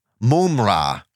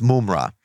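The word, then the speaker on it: Mumm-Ra: Mum-Ra. Mum-Ra. That's how you say it classily, is Mum-Ra.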